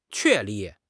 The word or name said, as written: 确立